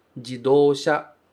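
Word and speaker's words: jidosha (自動車): Carro ou automóvel em Nihongo se diz jidosha. Jidosha. A gente usa três caracteres diferentes para formar esta única palavra.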